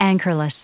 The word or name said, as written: anchorless